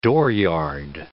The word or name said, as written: dooryard